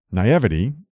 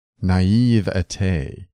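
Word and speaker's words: naïveté: Naivety is pronounced naivete, not naivety. Regards.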